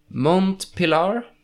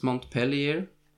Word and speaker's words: Montpellier: and those are mont pilar uh, and i have heard i I pronounced that very wrong in France, in French. Uh, but I'm gonna say it like it's spelled, and it's Montpellier. Uh, it's held 23rd of March on the Saturday, and also there's some fencing going on on the Sunday.